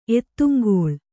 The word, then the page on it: ಎತ್ತುಂಗೋಳ: ettuŋgōḷa